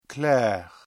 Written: clair

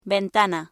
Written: ventana

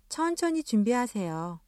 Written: 천천히

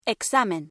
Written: examen

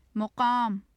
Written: maqam

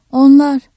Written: onlar

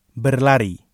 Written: berlari

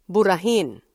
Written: burahin

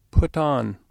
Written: put on

